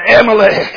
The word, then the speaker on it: Amalek: Amalek, I knew I was saying it wrong, but anyhow, it says, and it came to pass when Moses held up his hands, that Israel prevailed.